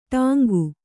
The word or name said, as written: ಡಂಕು